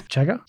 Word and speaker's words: chaga: Chaga? – Chaga? I say chaga, yeah.